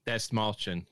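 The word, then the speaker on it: Dastmalchian: David Desmalch. How do you pronounce that shit? – Desmalchin, yeah.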